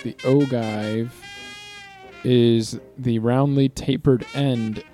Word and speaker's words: ogive: Says here, the pronunciation is ogive. – Ogive. – Huh. Ogive.